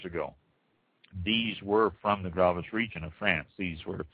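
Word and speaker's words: Graves: Barrels of the Gravis region is Gravis, how it's pronounced, were shipped to England during the English reign over France back in the early, between 1152 and 1453.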